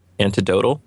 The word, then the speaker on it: anecdotal: anecdotal. – See, I left the C out.